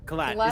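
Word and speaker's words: Qhlat: And look, Lieutenant Colt, you said? Colt? – Kalat. Kalat is how I'm pronouncing it. It's Q-H-L-A-T, Kalat.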